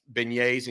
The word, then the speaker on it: beignet: Beignets or king cake? – You got to say it right. It's beignet. – Beignet, excuse me. – Beignet. Come on now.